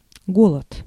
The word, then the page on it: hunger: American English; /ˈhʌŋ.ɡɚ/